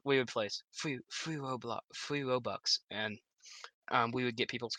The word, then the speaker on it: Robux: Being a 13-year-old, I spent like $50 on Robux. Is that how you pronounce it? I can't pronounce it. – Yeah, Robux.